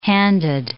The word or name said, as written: handed